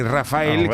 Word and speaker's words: Raphael: Hablamos del grandísimo, del enorme Rafael, que se pronuncia así a pesar de que se escribe Rafael, que tiene ahí una H en medio, que eso no vale para nada.